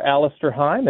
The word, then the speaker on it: Alastair Heim: I would just do a search for Alastair Heim. It's A-L-A-S-T-A-I-R-H-E-I-M.